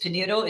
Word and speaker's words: Pinheiro: And there's this one guy I wanted to tell you about, Eddie Pinheiro. Do you know who he is? – Mm-mm. Highly recommend. Sounds familiar. How do you say his last name? – I think it's Pinheiro. It's Pinheiro. He's on YouTube.